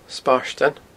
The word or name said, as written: Spairtean